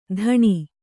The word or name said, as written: ಧಣಿ